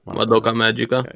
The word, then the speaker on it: Madoka Magica: All right, Madoka Magica. All right, that's how you say it. – Madoka Magica.